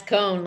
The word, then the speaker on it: Kohn: Nah, it's Kohn, like pine cone, ice cream cone.